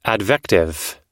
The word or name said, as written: advective